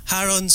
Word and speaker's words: Haron: Haron sir. – Tama? – Haron. – With emphasis on the H. – Haron. – Remember? – Kailangan ihagod mo.